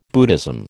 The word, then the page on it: Buddhism: American English